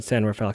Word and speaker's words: San Rafael: Stroke 9 with Little Black Backpack from San Rafael, California. What do you think of that song? – I mean, it wasn't horrible. – It wasn't horrible. Good times. And yes, I said San Rafael, California because I'm from the Bay Area and that's how we do.